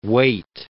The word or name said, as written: Waite